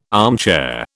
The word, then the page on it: armchair: British English